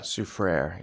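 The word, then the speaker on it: Soufrière: You had to tell me how to pronounce this town name, Soufriere, St. Lucia. – Soufriere, yeah.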